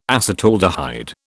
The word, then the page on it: acetaldehyde: British English